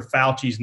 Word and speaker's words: Fauci: You're supposed to say it like you say F-O-W. Well, that is Fauci.